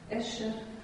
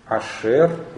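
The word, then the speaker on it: Ашер (אשר): Эшер. – Ашер, ударение. На хатеф патах не может быть ударения. Да, это же всегда безударный и редуцированный.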